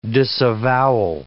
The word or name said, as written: disavowal